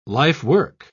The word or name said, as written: lifework